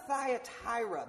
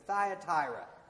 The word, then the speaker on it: Thyatira: See, in the town of Thyatira, that's how you pronounce it. Everyone say it with me, Thyatira.